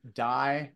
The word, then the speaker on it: Dai: And it's just my name, Dai Manuel. Just remember, it's spelled D-A-I and then Manuel, M-A-N-U-E-L.